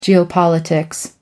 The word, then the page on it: geopolitics: American English